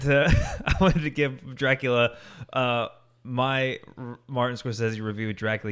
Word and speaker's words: Scorsese: Scorsese. How did he say his name? I always said Scorsese, but he said it like Scorsese, right? – Yeah, or something like that.